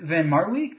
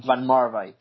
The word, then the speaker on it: van Marwijk: But also, these rumors as Bert van Marwijk, did I pronounce his name right? I've always read his name. I haven't said his name. – Van Marwijk, yeah.